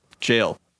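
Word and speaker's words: Gael: Yeah, but like, okay, the ever, I think it's G-A-E-L. How do you say that? – I always said Evergoal. – Jail. It's Everjail.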